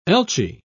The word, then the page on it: elchee: British English